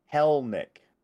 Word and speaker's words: Kelenic: It's Kelenic. Second E is silent.